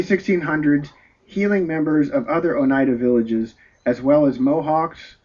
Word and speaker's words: Oneida: Oneida, is that how you pronounce it? – Oneida Indian tribe, and traveled the Hudson River in the early 1600s, seeking members of other Oneida villages.